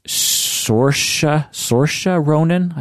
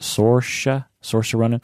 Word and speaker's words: Saoirse Ronan: The movie stars Saoirse Ronan, I think is how you pronounce it. Saoirse Ronan.